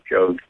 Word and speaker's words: Jogues: do you say it Jogues? Is that how you pronounce it? Jogues, Father Jogues.